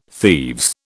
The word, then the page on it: thieves: British English